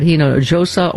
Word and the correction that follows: Hinojosa: You pronounced the J. – Don't pronounce that. – Pardon me. – You know, it's H sound. – Pardon me. – Like San Jose.